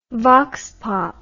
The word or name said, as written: vox pop